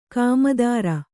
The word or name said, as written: ಗೋಮೂತ್ರ